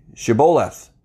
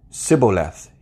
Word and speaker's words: shibboleth: And they would say, Shibboleth. But he would always say, Sibboleth. He couldn't say it right.